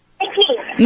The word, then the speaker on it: Nikki: Hi, this is Nikki. I think the answer is a hair dryer. – Nikhil or Nikki? – Nikki. – Nikki. How do you spell it? – N-I-K-K-I.